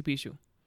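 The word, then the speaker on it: pixação: You see buildings with graffiti, and then you see bicho. And for those who want to, like, look into it, let me tell you how to spell it. It's actually, it doesn't sound how it's supposed to be spelled. It's P-I-X-A-C-A-O.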